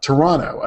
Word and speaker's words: Toronto: And one of our good friends up in Canada, McKay from Toronto, and I learned that was the proper way to pronounce it, Toronto. You never pronounce the second T.